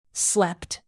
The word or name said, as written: slept